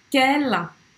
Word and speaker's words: quelle: Mas em francês, quando eu estou falando de algo no feminino, eu vou falar quela, tá? Com dois L's e E. A pronúncia é igual. Na fala é igual.